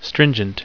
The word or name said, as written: stringent